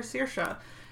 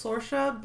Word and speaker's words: Sorcha: Tonight I'm going to be playing a couple of characters, some NPCs, and also my new character, Searsha. It was Sorsha, but it turns out the pronunciation is Searsha.